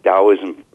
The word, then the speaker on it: Taoism: like Taoism, for instance, spelled with a T, even though it's pronounced as a D,